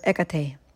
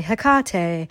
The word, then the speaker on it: Hecate: However, none of these is the version that's been popularized on social media as of late, which makes it sound like her name rhymes with a popular Mexican beer brand, Tecate. And I cringe so hard every time I hear someone say Hecate.